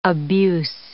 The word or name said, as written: abuse